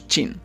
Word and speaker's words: Qin: Este nombre lo introdujeron los árabes en Europa, ya que ellos le llamaban así por una dinastía de gobernantes chinos llamados Qin, escrito Q-I-N, ya que en chino, o mejor dicho en pinyin, que es el sistema para transcribir los caracteres chinos al alfabeto latino, la Q se pronunciaría algo así como nuestra CH.